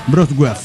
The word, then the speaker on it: Wrocław: Dice, hola amigos de la nave, les escribo desde la hermosa y siempre lluviosa ciudad de Wrocław, que por alguna razón se pronuncia Wrocław.